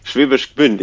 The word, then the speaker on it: Schwäbisch Gmünd: Schwäbisch Gmünd. Ich habe auch mal eine Zeit lang in Stuttgart gelebt, aber ich bin jetzt vor kurzem quasi auch nach Schwäbisch Gmünd gezogen. Genau. – Es ist Schwäbisch Gmünd.